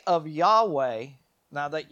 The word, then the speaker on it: Yahweh: Yeah, Y H W H. But our pronunciation and how we pronounce it today is Yahweh.